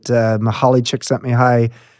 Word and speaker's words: Csikszentmihalyi: Yeah, the thing it does, it puts them in the flow zone such that they can, tap into that river that Mihaly Csikszentmihalyi wrote about in Flow, his book, famous book.